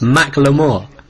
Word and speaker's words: Macklemore: Is it actually pronounced Macklemore? – Macklemore, yes.